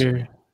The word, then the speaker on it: Imgur: It's Imgur. Their site explicitly says it's pronounced Imgur.